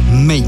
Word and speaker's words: Meyn: Por aquello de googlearlo, se escribe M-E-Y-N. May, desde Morelia, Michoacán.